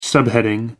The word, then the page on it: subheading: American English